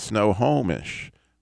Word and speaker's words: Snohomish: Am I saying it right, finally? – No. – Well, say it for me. – Snohomish. – Oh, I put too many syllables in it. Snohomish.